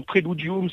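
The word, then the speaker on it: Präludium: Alors je vous laisse peut-être les prononcer parce que je vais peut-être les écorcher là, Praladium. – Ah non, c'est très simple. C'est très simplement Préludium, ça veut dire prélude.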